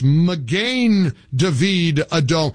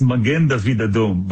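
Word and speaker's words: Magen David Adom: It's Magin David Adom. What's the proper pronunciation? – So you got it right on the second time. It's Magin David Adom.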